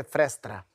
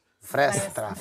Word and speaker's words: fresta: Fresta, parece que é frestra. Fresta. – E eu falei meio... Se eu for carioca mesmo, é fresta. – Fresta. – Fresta. – Isso. – Quase festa, mas é fresta.